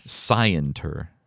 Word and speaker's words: scienter: Spelled like science with a T, right? S-C-I-E-N-T-E-R.